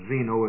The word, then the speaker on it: Zeno: The word Zeno is spelled as it's pronounced, Z-E-N-O.